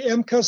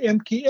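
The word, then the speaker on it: Ehmke: Howard Emke, some say Emke, some say Emke. Emke is an American pronunciation. Emke is comes from a family of German immigrants.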